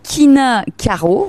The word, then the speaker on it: Kinakaro: Venez à la cave de l'Enfort à Narbonne, vous demandez le quinacaro, voilà, ça s'écrit K-I-N-A-K-A-R-O, et vous verrez, c'est le cadeau original pour votre papa.